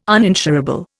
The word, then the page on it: uninsurable: Indian